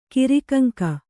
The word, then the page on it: ಕಿರಿಕಂಕ: kannada